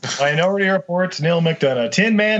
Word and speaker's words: Neil McDonough: Neil McDonald? How do you say that? – Oh, he plays a bad guy in everything. – The steely blue-eyed Neil McDonough.